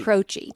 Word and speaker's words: Croce: Croce. – Jim Croce. – Yeah, I knew you didn't say it like it's spelled because it's Croce, but Croce.